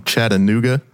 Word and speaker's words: Chattanooga: Katie's coming to us all the way from Chattanooga, Tennessee.